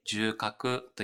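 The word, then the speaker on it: 縦隔: はい、縦に隔てると書いて重核と読みますが、重核という臓器があるわけではなくて、左右の肺の間にある空間のことを重核と呼んでおります。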